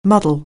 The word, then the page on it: muddle: anglais